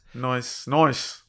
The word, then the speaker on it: Noce: I did like partnering Alicia up with is it Noice? How do you Noice. Pronounce his name? N-O-C-E. – Yeah. Noice.